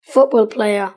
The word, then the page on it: football player: British English